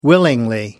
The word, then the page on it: willingly: British English